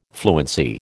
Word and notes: fluency: American English

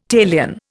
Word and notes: talion: Indian

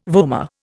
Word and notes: vomer: Indian

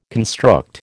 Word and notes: construct: American English

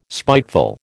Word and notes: spiteful: American English

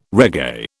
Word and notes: reggae: British English

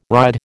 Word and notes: ride: American English

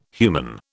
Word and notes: human: British English

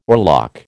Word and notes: oarlock: American English

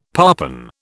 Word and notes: parpen: British English

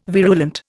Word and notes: virulent: Indian

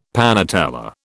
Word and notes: panatella: British English

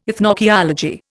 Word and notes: ethnoarchaeology: Indian

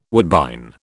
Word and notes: woodbine: British English

